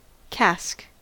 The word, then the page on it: cask: American English